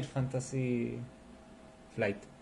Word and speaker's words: Fantasy Flight: Yo no sé qué tanto tanta cercanía pueden tener Fantasy Flight.